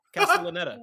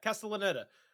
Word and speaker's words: Castellaneta: The narrator at the beginning of the movie that's talking in the Italian-American accent for the dinosaurs is none other than Dan Castellaneta. Did I say his name right? The guy that does Homer Simpson's voice. Castellaneta? – No shit. – Castellaneta, wow. Right? Yeah, Castellaneta.